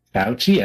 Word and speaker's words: Fauci: She's not getting the Fauci ouchie. It's so bad. Oh my god. – Oh shit! Now I know how to say the name. – Yeah. Right. – Fauci.